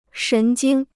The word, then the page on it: 神经: shén jīng